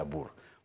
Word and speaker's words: Boer: Broek, que se pronuncia Brook, porque el diptongo OE en neerlandés se pronuncia U, como en Boer, que se pronuncia Bur.